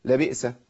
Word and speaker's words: لبئس: اقول لبئس، لبئس ما كانوا، لبئس ما كانوا، لبئس ما كانوا، في فرق.